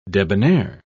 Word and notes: debonaire: British English

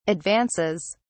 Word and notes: advances: British English

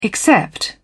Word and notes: except: British English

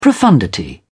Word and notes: profundity: British English

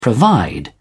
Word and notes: provide: British English

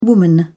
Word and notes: woman: British English

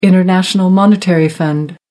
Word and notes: international monetary fund: American English

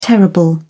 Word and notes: terrible: British English

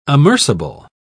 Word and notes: amerceable: British English

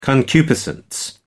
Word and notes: concupiscence: American English

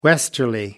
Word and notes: westerly: American English

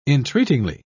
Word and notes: entreatingly: British English

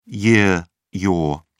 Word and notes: yr: British English

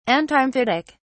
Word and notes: antimephitic: British English

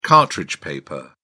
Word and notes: cartridge paper: British English

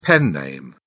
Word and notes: pen name: British English

